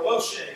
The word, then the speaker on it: Moshe: And so, when the Jews, even today, when they speak of Moses, or Moshe as they pronounce it, they always call him Moshe Rabbeinu.